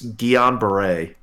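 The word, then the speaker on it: Guillain-Barré: Guillain Barre, I believe is how it's pronounced.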